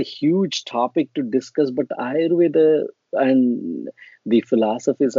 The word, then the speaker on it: Ayurveda: So Ayurveda, absolutely, you said it correctly.